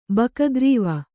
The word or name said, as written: ಬಕಗ್ರೀವ